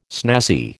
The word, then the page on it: snazzy: American English